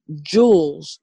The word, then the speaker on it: Julz: That's spelled N-O-B-L-E. Jules, J-U-L-Z.